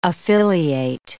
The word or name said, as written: affiliate